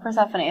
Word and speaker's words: Persephone: Oh no, Persephone is how you say it.